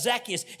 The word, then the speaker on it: Zacchaeus: We put the emphasis on the first syllable. And so his name is Zacchaeus. And this is what the Bible tells us about Zacchaeus.